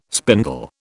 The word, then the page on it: spindle: British English